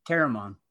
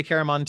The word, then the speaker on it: Caramon: Caramon. – Yeah, I say Caramon too.